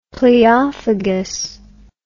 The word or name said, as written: pleophagous